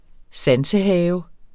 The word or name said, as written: sansehave